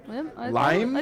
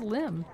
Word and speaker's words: limn: Lime? I thought it was limb. – Lime? – Isn't it limb? – I don't know. – L-I-M-N. – I didn't use that correctly. – If you know how to pronounce limb.